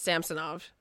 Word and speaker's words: Samsonov: Yeah, they said that at the beginning of the year that he wanted it pronounced Samsonov. So, yeah.